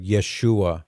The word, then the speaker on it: Yeshua: Yeah, that was a word that was, Joshua is the more traditional way to say it. We say Jesus in English, but Yeshua would be more closely how you would pronounce it.